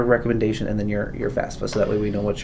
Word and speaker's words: FAFSA: And oh yes, FAFSA. I knew I was missing. There was three. – You pronounced it better than I did. FAFSA.